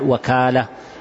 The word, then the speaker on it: وكالة: إذن الوكالة وهي تنطق بفتح الواو وكسرها يقال وكالة ووكالة، أي بالفتح وبالكسر وكلاهما صحيح،